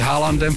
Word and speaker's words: Haaland: Přátelé, do kina vtrhnul Spider-Man z Brusu Nový den, Tom Holland, mně se líbilo v televizi, v americké televizi, kdy s Haalandem fotbalistou, protože ono se to vyslovuje Haaland, tak abych mu vlastně měl říct Tom Holland, vyslovuje se to stejně a akorát, že každý dělá úplně jiné řemeslo.